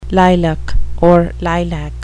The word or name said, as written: lilac